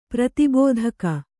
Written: ಪ್ರತಿಬೋಧಕ

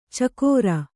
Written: ಛಿಕ್ಕಾರ

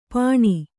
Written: ಪಣಿ

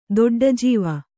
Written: ದೊಡ್ಡಜೀವ

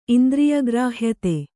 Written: ಇಂದ್ರಿಯಗ್ರಾಹ್ಯತೆ

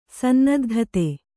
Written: ಸನ್ನದ್ಧತೆ

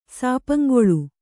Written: ಸಾಪಂಗೊಳು

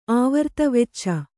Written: ಆವರ್ತ ವೆಚ್ಚ

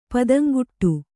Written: ಪದಂಗೆಡು

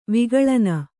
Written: ವಿಗಳನ

